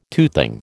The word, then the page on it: toothing: American English